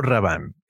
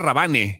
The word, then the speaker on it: Rabanne: No, pero era Rabanne. O sea, que sea Rabanne. Yo lo pronunciaba Rabanne y creo que sí la banda decía Rabanne. Como decir Paco Rabanne. Paco Rabanne. Pues ahora que lo veo, pues sí era Rabanne, pero pues sí, todos le decíamos Paco Rabanne.